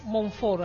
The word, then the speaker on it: Montfort: O sea, ahí creo que fue San Pío de Pietrelcina o San Luis de Montfort, no, Montfort, así se pronuncia correctamente.